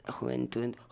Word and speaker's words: Juventud: si je prononce bien en espagnol. Je suis pas sûr. – Juventud. – Juventud. Ah ouais.